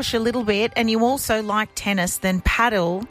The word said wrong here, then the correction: padel: Yeah, but sorry, can I just stop you? It's padel. – What? – Many people pronounce it padel.